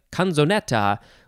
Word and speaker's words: canzonet: Next, we have canzonet. Canzonet. Yes, I believe that it is how it's pronounced.